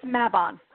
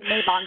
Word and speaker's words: Mabon: I think it's Mabon. I just discovered that today. I was pronouncing it Mabon before.